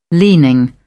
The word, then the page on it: leaning: British English